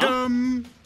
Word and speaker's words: Tudum: Tudum. – Oh. – Tudum. – Tudum? – It's spelled T-U-D-U-M.